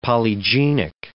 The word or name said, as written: polygenic